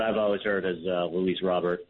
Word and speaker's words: Luis Robert: I've uh, so I've always heard it pronounced as uh, Luis Robert.